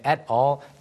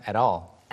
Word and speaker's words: at all: 네, 발음이 이제 마지막에 at all. 미국에서는 그냥 at all. – At all. At all. Like you open your mouth. At all. – 네, 이렇게 발음하는데 영국에서는 at all.